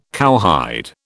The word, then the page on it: cowhide: British English